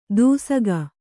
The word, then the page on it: ದೂಸಕ: dūsaka